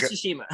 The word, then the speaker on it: Tsushima: Ghost of Tsushima. – Ghost of Tsushima.